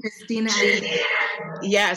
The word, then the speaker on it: Christina Aguilera: And that Christine Aguilera, is that how you say her name? – Christina Aguilera.